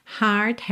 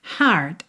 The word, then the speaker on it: heart: Três palavras aí iniciadas com H, heart has heard. Atenção, olha que interessante, heart, H-E-A-R-T, heard, H-E-A-R-D, né? Só muda a última letra na escrita, mas na pronúncia a gente tem bastante diferença, né?